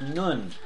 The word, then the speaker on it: Nun: I know it looks like Nun, but that's not how it's pronounced. It's Nun. And it's the Hebrew equivalent to our N. It has the N sound, children.